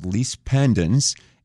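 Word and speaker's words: lis pendens: One of them is called lease pendens. And even though it's pronounced lease, it's spelled L-I-S. Pendens is P-E-N-D-E-N-S.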